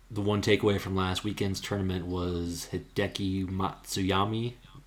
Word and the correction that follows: Matsuyama: Matsuyama. I'm really bad at pronouncing his name. I think say said different every time, but he's he putted really badly uh, last last weekend, and he's been playing well.